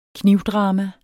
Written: knivdrama

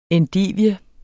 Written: endivie

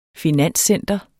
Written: finanscenter

